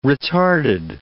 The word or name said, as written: retarded